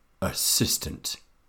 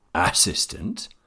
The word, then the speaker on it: assistant: The stressed syllable in the word assistant is the second one. If I stress the first assistant or last assistant, it is difficult to understand.